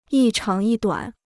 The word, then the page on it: 一长一短: yī cháng yī duǎn